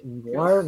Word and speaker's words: Nuar: Eh, primero que nada, recuerden ir a visitar la página de Instagram de Noir, noir.brand. Los encontrarán en Instagram, se escribe N-U-A-R.brand, B-R-A-N-D. Repito, n UAR.BRAND en Instagram y es una marca de ropa, ¿no?